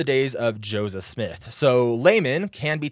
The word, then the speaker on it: Luman: But there's also someone closely tied to the Smith family called Laman Walters. It's spelled L-U-M-A-N, but it was pronounced as Laman and sometimes written as Laman, L-A-M-A-N.